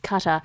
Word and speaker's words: Qatar: Sorry, it's pronounced Qatar.